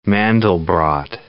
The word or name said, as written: Mandelbrot set